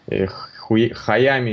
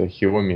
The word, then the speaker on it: Xiaomi: Вообще, я сейчас считаю, что Sony лидеры на рынке, и никакие Canon'ы, там, Хуями, или как там, Хуями, вообще ни в сравнении нет. Ну, я не помню, как эта фирма правильно произносится. – Xiaomi?